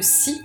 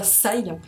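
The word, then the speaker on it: Cy: Alors, les Radium Girls, c'est une BD de Si, à Cassiriel, donc qui ne se prononce pas Sai, comme je l'ai entendu tout à l'heure.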